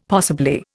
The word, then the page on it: possibly: Indian